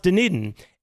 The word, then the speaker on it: Dunedin: No, it's spelled like, it's a town. It's spelled like Dunedin, right? Like Dune, D-U-N-E. – Dunedin. And then like Din, oh, D-I-N. Got it. – But it's pronounced Dunedin.